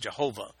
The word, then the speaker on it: Yahweh: In the Old Testament, all caps, Lord, is always a translation of the Hebrew word Yahweh, or sometimes pronounced Jehovah.